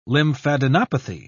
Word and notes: lymphadenopathy: British English

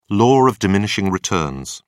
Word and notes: law of diminishing returns: British English

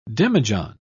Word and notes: demijohn: British English

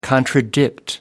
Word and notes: contradict: American English

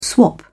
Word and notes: swap: British English

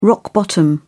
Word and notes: rock-bottom: British English

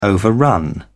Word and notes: overrun: British English

